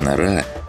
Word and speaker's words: Нора: Светлана Норкина, более известная в монастыре как Нора, с ударением на втором слоге, убрала ладони с клавиш ноутбука, введя последнюю строку кодовой последовательности.